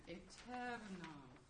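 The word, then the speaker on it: aeternam: We want to be sure that we're using correct word accent, eternam, and eleison, and not eleison. Now, to that end, let's go back a little bit.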